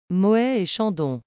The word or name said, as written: Moet Chandon